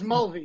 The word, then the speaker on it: Mulvey: No, you didn't, but it's close. It's Mulvey.